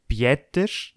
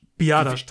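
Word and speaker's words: Biadasz: Bietisch. – Biadasch, Tyler Biadasch. Biadasch, genau, so wird es ausgesprochen.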